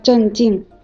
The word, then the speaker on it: 镇静: 所以在说的时候，大家一定要注意区分声调。 – 啊，遇到问题要保持镇静，不要震惊。